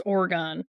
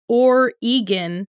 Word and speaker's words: Oregon: Just remember that it's Oregon, not Oregon. It literally says G-A-W-N. It was written for Gone. – People with our accent. Yeah. Place the stress on the first syllable and keep the G hard, as in gun.